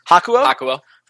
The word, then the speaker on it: Hakuho: Renegades have Hakuho, I believe is how you pronounce that. – Hakuo.